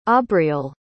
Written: aubrielle

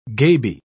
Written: gaby

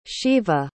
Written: Sheva

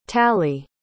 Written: talli

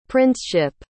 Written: princeship

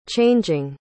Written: changing

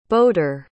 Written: bodor

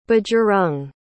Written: bajarang

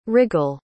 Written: riggle

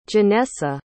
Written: jennessa

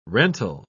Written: rental

